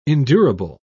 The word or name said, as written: endurable